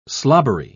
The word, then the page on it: slobbery: British English